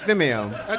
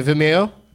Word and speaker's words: Vimeo: Is it Vimeo? – Yeah, it's Vimeo. – Okay. – You're going to back off the I-M sound a little bit, though. – Okay, Vimeo? – No. Vimeo. Just Vimeo.